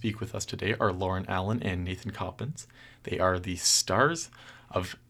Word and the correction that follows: Copens: Did I pronounce that correctly? Or Copens. Copens. I should have told you. – no worries. So Nathan Copens,